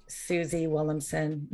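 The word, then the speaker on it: Suzy Willemsen: So it's at Susie Willemsen, name is spelled Suzy Willem as in Mary, Sen as in Nancy.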